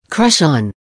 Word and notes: crush on: American English

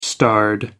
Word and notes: starred: American English

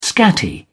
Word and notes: scatty: British English

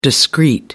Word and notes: discreet: American English

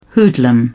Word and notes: hoodlum: British English

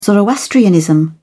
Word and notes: zoroastrianism: British English

